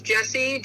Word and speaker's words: Jessi: A-S-C-E-N-D, on all social media platforms. And you can find me at Just Jessi, G-O-P. And my name is J-E-S-S-I.